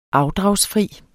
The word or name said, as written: afdragsfri